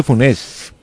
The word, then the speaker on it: Funès: Yo decía Louis de Funès. – Es exactamente como usted lo dice, pero la U, Funès. – Así como F. – Funès. – Porque la E tiene acento invertido, por lo cual es, si no tuviera acento sería Funès.